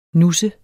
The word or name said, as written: nusses